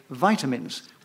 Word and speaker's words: vitamins: and the subject now is vitamins, or as some people used to say, vitamins, which is the correct pronunciation, because it comes from the word vitality.